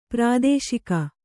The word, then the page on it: ಪ್ರಾದೋಷಿಕ: prādōṣika